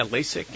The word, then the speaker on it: LASIK: We want to help you if there's any questions that you want to know about LASIK surgery. Am I saying it right? – Yeah, LASIK.